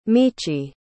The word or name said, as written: michi